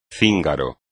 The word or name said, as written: cíngaro